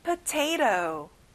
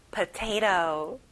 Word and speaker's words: potato: 감자, potato. 여기도 끝에 T 발음이 안 나죠? potato.